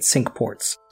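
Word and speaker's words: Cinque Ports: Now Dampier, he's in command of 2 ships, the St. George and the Cinqports. I think that's how you say it. Cinqports. – Sounds right.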